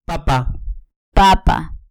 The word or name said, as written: papa